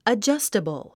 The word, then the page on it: adjustable: ədʒʌ’stəbl; アジャスタブル